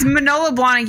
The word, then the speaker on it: Manolo Blahnik: Manola Blanca. – Manolo Blahnik. – Okay. – It is. It's Manolo Blahnik. You have this spelled wrong. God.